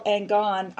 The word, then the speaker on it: Angone: and hopefully we'll be sharing conversations with Paul Angone. I'm sorry, I forgot to ask you, Paul, is that how you say your last name? – It's Angone. – Angone. Paul, yeah. Angone, Italian.